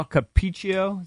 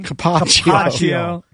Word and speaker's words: Capaccio: This is what Sal Capaccio, is that his name? – Capaccio. – Can't say his name, sorry.